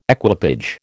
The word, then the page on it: equipage: American English